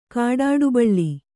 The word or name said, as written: ಕಾಡಾಡುಬಳ್ಳಿ